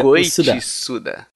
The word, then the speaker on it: Goichi Suda: Ô, ô, como é que fala o nome dele? É Goichi Suda? Goichi. – Goichi Suga. – Goichi Suda. Goichi Suda. – Suda. – É, Olha Suda aí.